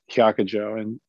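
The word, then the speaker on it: Hyakujō: So Baijiang, who in Japanese, whose name is pronounced Hyakujo in Japanese,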